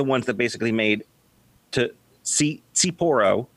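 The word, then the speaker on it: tsipouro: Its roots can actually be dated to a sort of brandy called Sipuro, or Sipuro, T-S-I-P-O-U-R-O. I know the T is silent, but I'm not totally sure how to pronounce it. – Yeah, it sounds like it's supposed to be pronounced like Sapporo.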